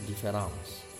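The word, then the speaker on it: différance: gitu yang kemudian juga nyambung dengan difference uh, eh, di bahasa Perancisnya dibacanya pengucapannya difference uh, eh, nulisnya mirip di dalam bahasa Inggris difference uh, tapi e yang pertama itu ada curapnya difference jadi bacanya e difference nah uh,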